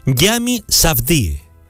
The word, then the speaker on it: Yami Safdie: Ah, y no te compliques con el nombre. Se pronuncia tal y como se escribe, Yami Safdie.